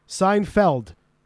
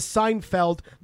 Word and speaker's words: Seinfeld: The show takes place basically where he is, and he said the name is Seinfeld, not Field. – Hey. – All right. – He's breaking up. He said Seinfeld. – He said Feld. Just like Feldman.